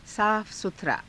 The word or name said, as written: saafa sutharaa